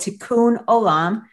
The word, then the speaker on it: tikkun olam: the very first one is a concept it's a hebrew word to- called tikkun olam uh, i'll go ahead and spell it t-i-k-k-u-n o-l-a-m Tikkun Olam literally means repairing the world.